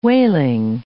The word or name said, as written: whaling